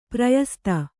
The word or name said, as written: ಪ್ರಯಸ್ತ